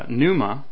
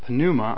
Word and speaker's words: pneuma: So in the Greek, when we read about the Holy Spirit, as I said, it's pneuma, which is the, or pneuma, I think is how Strong's is saying to pronounce it.